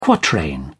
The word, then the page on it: quatrain: British English